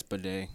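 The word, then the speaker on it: Badet: Fourth team, Jeff Bidet. Is that how you say it? – Yeah, it's Bidet. – Bidet, Oklahoma.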